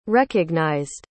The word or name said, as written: recognized